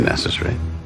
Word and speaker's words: necessary: Natomiast Amerykanie wymówią to słowo przez 4 sylaby i ciekawe jest to, że niektórzy Brytyjczycy też je tak wymawiają. A więc po brytyjsku mamy 2 poprawne warianty: i necessary, i necessary.